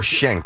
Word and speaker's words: Schenck: E-N-C-K. How would you say that? – Shenick? – Yeah, I don't know. – Shenick or Schenck? – I would not think. – No, Schenck. Schenck. – Maybe it is Schenck.